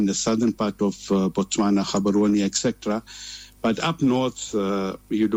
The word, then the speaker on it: Gaborone: No, it's not Gaborone, it's Gaborone. You know what, Tajweed, if I can put it there.